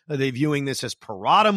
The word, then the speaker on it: Parada: But when they drafted Parada, is that how you pronounce it, by the way, Kevin Parada, the catcher they drafted? – Yep. All right. Yep.